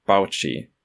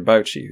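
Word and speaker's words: Bauchi: There is a guy in the book called Dr., and I'm going to pronounce it this way, Dr. Baoqi, because it's B-A-U-C-H-I. C-H-I in pinyin could be chi, but B-A-U we don't have in pinyin. So I was thinking Baoqi, Dr. Baoqi, who's this revered scientist and doctor.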